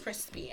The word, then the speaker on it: Chrispy: And my name is Crispy, and that's spelled C-H-R-I-S-P-Y.